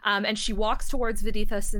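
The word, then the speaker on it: Vadithas: Okay, Vidithus. So it's V-A-D-I-T-H-A-S. – Gotcha. – Vidithus.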